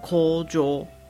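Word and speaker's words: 工場: 日文的工厂呢念作 Kojo。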